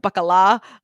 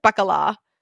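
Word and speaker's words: baccalà: And like the Italian pronunciation is obviously different than our Portuguese word for it, but the, for them it's like bacala or something like that.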